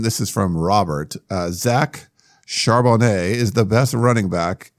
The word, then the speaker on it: Charbonnet: He is, and good pronunciation, by the way, is Charbonnet.